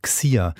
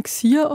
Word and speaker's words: Xier: also mit X-I-E-R, also Xier. Ich kann es gar nicht genau aussprechen. Wie spricht man das aus? – Genau, da spricht man Xier aus. – Xier. Also Xier statt sie, er, also als Pronomen.